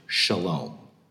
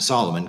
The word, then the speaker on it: Shalom: That's why his name is actually Shalom. We pronounce it Solomon because, you know, we're Americans, I guess.